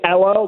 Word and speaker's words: Elo: Yeah, his name is Elo, like Carmelo. Elo Moduzzi.